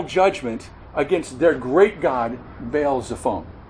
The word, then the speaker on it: Baal Zephon: Baal Zephon, we pronounce it.